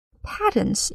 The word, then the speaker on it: patent: 一个叫做 patents, 一个叫做 royalties。 patents 表示专利，把它还原成单数叫做 patent, 注意发音 pa, 它是一个梅花音的 a, Patent,